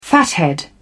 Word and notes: fathead: British English

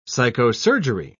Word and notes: psychosurgery: British English

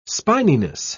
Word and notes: spininess: British English

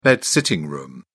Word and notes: bed sitting room: British English

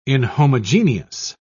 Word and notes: inhomogeneous: British English

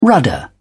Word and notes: rudder: British English

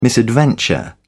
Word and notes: misadventure: British English

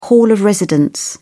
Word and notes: hall of residence: British English

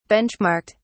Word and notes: benchmarked: British English

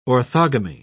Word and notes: orthogamy: British English

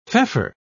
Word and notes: feoffer: British English

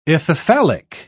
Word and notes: ithyphallic: British English

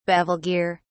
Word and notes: bevel gear: British English

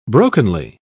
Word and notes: brokenly: British English

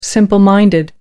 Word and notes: simple minded: American English

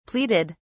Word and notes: pleaded: British English

